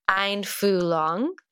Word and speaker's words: Einfühlung: So this actually comes from a German word pronounced Einfühlung. I've never known how to pronounce it before.